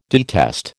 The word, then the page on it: detest: American English